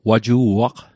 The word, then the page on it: waju'aq: Mi'gmaq